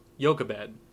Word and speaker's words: Yocheved: Yocheved, apparently, Yocheved is the Hebrew pronunciation.